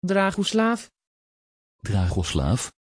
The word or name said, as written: Dragoslav